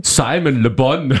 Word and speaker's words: Simon Le Bon: So wird er ausgesprochen. Simon Le Bon.